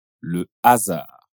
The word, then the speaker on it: le hasard: Porém, palavras comme « la honte ou le hasard » também começa avec la letra H, mas a pronúncia un um peu pouco diferente. Consegue reparar? Les hommes, l'hôpital, la honte, le hasard.